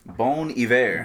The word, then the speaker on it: Bon Iver: Bon Iver? – Bon Iver.